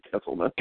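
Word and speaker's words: Gsellman: But Syndergaard, DeGrom, Harvey, Mats, how do you pronounce Gesselman? Selman? – I think it's Gesselman.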